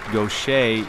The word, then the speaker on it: Gauthier: Would you please welcome from Nashville, Tennessee, Mary Gaucher. – She was raised in Louisiana. Her name, pronounced Gaucher, is spelled G-A-U-T-H-I-E-R.